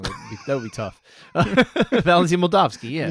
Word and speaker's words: Moldavsky: Moldavsky, that's it.